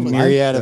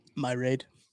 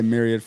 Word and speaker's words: myriad: Well, listen, I didn't know how to pronounce it. – How did you think? – My raid. I flipped the A and the I.